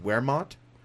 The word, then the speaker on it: Wehrmacht: And got into, I believe it's pronounced Wehrmacht.